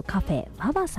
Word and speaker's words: VAVA: そして南青山にフレンチレストランシルクさん、 CILQ と書いてシルクさん、そして霞が関にエビ専門店シュリンプガーデンさん、そして日本橋高島屋さん新館にございますカフェババさん、 VAVA と書いてね、カフェババさんとございまして、この中からですね、今月は銀座にございますシーフードレストラン火山さんのペアお食事券 8000 分となっております。